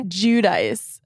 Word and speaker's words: Giudice: Giudice. But this is where it got fucked up. Giudice, I think, is the Italian way to say it.